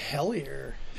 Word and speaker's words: Hellier: We're going to dive into Hellier. Hellier, hell yeah. – Hellier, hell yeah. That's spelled H-E-L-L-I-E-R.